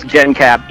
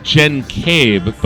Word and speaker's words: genCAB: So now it's just genCAB, even though it's cable. It's not even cable. – So it's genCAB, but even you don't say that. – Yeah, even I say it wrong.